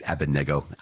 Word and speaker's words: Abednego: It's Abednego, I hear, because I believe the accent is on the third syllable.